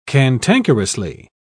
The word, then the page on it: cantankerously: British English